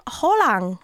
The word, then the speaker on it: Haaland: Du kan säga å. Erling Haaland. Haaland.